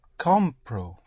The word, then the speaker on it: compro: Compraro. – No, you don't add. Comprar, you only have two syllables, yes? You push down. – Compro. – Right. Compro. Push down.